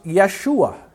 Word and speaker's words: Yeshua: The Lord's name is pronounced Yeshua in Hebrew. Joshua is how we say it in English. Jesus is the Greek.